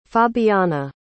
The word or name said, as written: fabiana